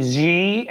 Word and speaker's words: Xi: I mean, if there's going to be ever a man more desperate for Vladimir Putin and God damn it, Xi. Did I say it right? – Xi, President Xi. Xi. – I said Xi. I got it. Awesome. – Xi, like almost a J sound.